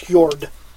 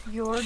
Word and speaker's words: Jord: Whose name is Jord. – Jord.